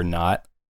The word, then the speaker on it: Nacht: There's also a great website called Yacht or Not. I encourage folks to check out. Not is spelled NACHT,